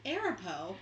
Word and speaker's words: Arapaho: It's pronounced Arapaho.